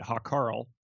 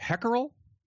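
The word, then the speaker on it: Hákarl: It's written Hakarl. So it is to the Americans, they're all going to pronounce it Hakarl. But I think it's closer to like Hackerel, mackerel almost.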